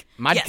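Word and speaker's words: Mädchen Amick: Uh, I don't know how to uh, Mad- say it like Madkin Amick. – Madkin yes. Amick.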